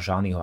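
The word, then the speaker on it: Jani: mimochodom pretekára Nila Janiho, alebo Nila Žaniho, ako ho niekde vyslovujú toto meno.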